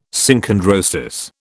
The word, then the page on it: Synchondrosis: British English